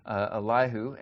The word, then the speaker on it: Elihu: That's how I'm going to pronounce his name. Elihu, and he's a younger man, so he's kind of been sitting through this.